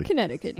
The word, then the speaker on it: Connecticut: Connecticut.